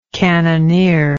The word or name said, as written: cannoneering